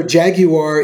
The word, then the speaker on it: Jaguar: You know, jag you are is the way the British pronounce what the car that we call Jaguar. – I didn't know that. – Yes. – Okay. – The British, the true British pronunciation for that is Jaguar.